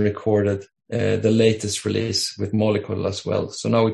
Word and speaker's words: Mollycoddle: Mollycoddle.